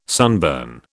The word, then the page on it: sunburn: British English